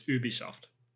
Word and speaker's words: Ubisoft: But like in France, though, it's Ubisoft, like ooh, and that's how we pronounce it in Montreal as well.